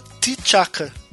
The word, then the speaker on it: T'Chaka: É, no, no filme, eu achei interessante porque assim, eu, eh, se escreve T apóstrofe Chaka. Então, tipo, não se sabe como pronuncia. Tipo, cê, cê, eu leio Chaka, mas no filme a Viúva Negra fala T T'Chaka entendeu? Como se o T não fosse mudo, então fosse, fosse fosse uhum.